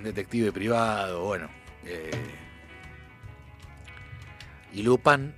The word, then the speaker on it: Lupin: Lupin, en realidad, Lupin, como lo pronuncian en Francia.